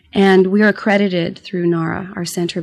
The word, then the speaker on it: NARHA: You belong to the NARHA, did I say that right? – NARHA. – NARHA, N-A-R-H-A, the North American Riding for Handicapped Association.